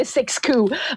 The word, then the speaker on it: Siskiyou: It's spelled S-I-X-K-U. So I pronounce it Siskiyou because I'm French and I can. So that's why I changed the pronunciation. Because six is six in French, so the number.